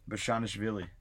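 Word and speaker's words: Bezhanishvili: And look at Georgie, I can't pronounce his name. – Bashanishvili. – Wow, great pronunciation.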